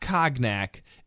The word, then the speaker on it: cognac: The question, how do you pronounce C-O-G-N-A-C? No, it is not cognac. It is a French word, actually. And the American pronunciation of that French word is cognac. Cognac. Just like ice cream cone. And just like the animal yak, cognac.